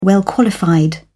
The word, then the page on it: well-qualified: British English